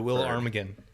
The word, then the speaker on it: Ptarmigan: The Willow Ptarmigan. It's named appropriately for being so progressive. – Willow Ptarmigan. – Silent P and T.